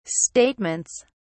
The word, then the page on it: statements: British English